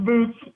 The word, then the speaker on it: Boots: It's pronounced boots, but I'm not offended. It actually sounded pretty good when everybody started chanting butts, butts, butts.